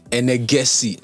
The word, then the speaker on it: Enegesi: Enegesi.